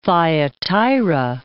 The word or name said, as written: Thyatira